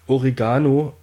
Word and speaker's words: Oregano: Laut Duden liegt die richtige Betonung auf dem E. Deshalb ist es falsch, das Wort auf dem O oder gar dem A zu betonen. Wer es richtig aussprechen möchte, betont das Wort Oregano auf dem E.